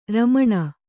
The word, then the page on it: ರಮಣ: Kannada